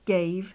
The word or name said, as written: gave